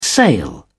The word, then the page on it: sail: British English